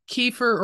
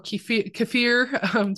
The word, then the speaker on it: kefir: There is many other things like sourdough is fermented, kefir or kefir, depending on how you pronounce it, kimchi.